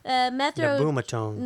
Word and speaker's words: nabumetone: Did I butcher it? Nabutone? – Nabumetone. – Nabumetone, okay.